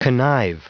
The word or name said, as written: connive